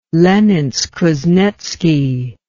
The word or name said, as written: leninsk-kuznetski